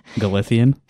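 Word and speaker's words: Galicia: Like, I feel I love Galician music. I think it's really beautiful. – Yeah, I can't weigh in on this. – Yeah, it's cool. Everybody go look it up. It's good. – Galician?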